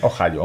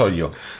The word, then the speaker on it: Ohio: Ni eso y eh, Ohio se pronuncia Ohio. Aunque no lo parezca, es, complicado, pero bueno, no es hoyo. No es hoyo. No se dice hoyo.